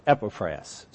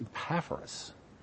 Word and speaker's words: Epaphras: Some wrongly pronounce it Epaphras. So if you've heard that, please correct it. It's Epaphras.